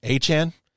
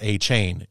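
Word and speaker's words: Achane: It's Achan. It's A-C-H-A-N-E, but I guess it's not A-Chain. It's Achan. That's how he wants it pronounced.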